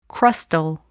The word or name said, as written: crustal